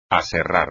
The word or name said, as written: aserrar